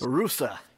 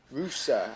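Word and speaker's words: Ruse: I mean, all I know about Dunaf Rus, if I'm pronouncing it correctly, is... – Rusa. – Rusa.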